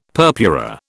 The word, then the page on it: purpura: British English